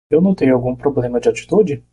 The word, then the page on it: notei: portoghese